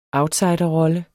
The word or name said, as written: outsiderrolle